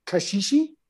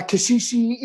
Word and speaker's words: caxixi: so you're holding them on the body so that you get because um, on these these shakers so these shakers are called caxixi these particular ones, which is a basket shaker, um, and it's got a piece of hard plant on the bottom.